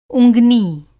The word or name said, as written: उँगनी